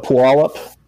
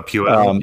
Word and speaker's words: Puyallup: And like we were in Seattle actually, it's not Seattle, but Puyallup. – Oh, Puyallup.